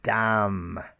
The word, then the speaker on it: damn: And also when we're looking at the letter N, we have a silent N, and it's usually after the letter M. Remember at the beginning I said to distinguish between M sound and the N sound. So let me give you some words that have usually M, N at the end, and the N is silent, and we only sound the M. For example, damn. It's a curse. Oh, damn it.